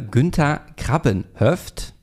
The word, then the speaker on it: Günther Krabbenhöft: Getroffen mit äh, Günther Krabbenhöft. Habe ich das richtig ausgesprochen, Günther? – Ja.